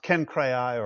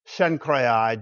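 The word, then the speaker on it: Cenchreae: It's pronounced Kenkrai or Shenkrai, depending on whether you use a hard C or a soft C in Latin.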